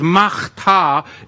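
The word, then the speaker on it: machtar (מחתה): The Hebrew word there is machtar.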